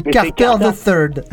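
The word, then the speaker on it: Carter III: Je pense qu'on dit Carter the Third. – Carter quoi ? – Voilà, Carter the Third. On va dire, c'est comme Richard the Third.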